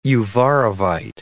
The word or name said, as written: uvarovite